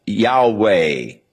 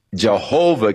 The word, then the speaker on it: Yahweh: So the general consensus of opinion is that the name was to be pronounced Yahweh. But somewhere along the line, the pronunciation of Jehovah came along, or Yehovah, and it has become more popular, but Yahweh is probably the correct pronunciation, though we do not know for sure.